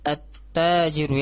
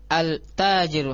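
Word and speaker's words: at-tajiru (التاجر): Jadi at-tajiru, ya, at-tajiru, bukan al-tajiru. Ha, ini keliru. Ya, bukan al-tajiru tapi at-tajiru.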